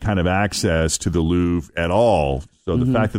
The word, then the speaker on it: Louvre: They even released a video for a track that was filmed inside the Louvre in Paris. Did I pronounce that right, Fritchie? – You did a good job.